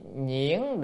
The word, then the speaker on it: nhuyễn: Là gì, nhuyễn động, chữ nhuyễn, chữ nhuyễn này còn đọc là nhu cũng được, chữ âm nhu cũng được, âm nhuyễn cũng được nha, âm nào cũng được, nhu động hay nhuyễn động cũng được, thôi đọc nhuyễn nó hay hơn.